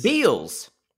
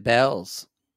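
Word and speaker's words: Beall's: B-E-A-L-L-apostrophe-S. – Beals. – But we know we pronounce it Bells.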